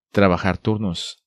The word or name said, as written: Trabajar turnos